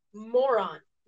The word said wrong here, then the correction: Morant: Mayron? How do you say it? – For John Morant. – John Morant. Yeah.